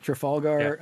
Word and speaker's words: Trafalgar: Now, if you look at, I don't know how to pronounce this correctly, but Trafalgar, I believe is how you say it. Trafalgar, yeah. Polling.